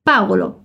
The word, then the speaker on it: Paolo: Na Itália, o nome Paolo é escrito com O. Não existe Paulo com U. Mas a sílaba forte continua no Pa. Paolo, beleza?